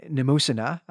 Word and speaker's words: Mnemosyne: their mother, Mnemosyne, is how I'll pronounce that, Brent, though also my Greek pronunciation, not always the best.